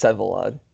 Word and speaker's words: Vsevolod: Vsevolod Skotnikov, or something along those lines? – At least Sevolod. The first V is silent.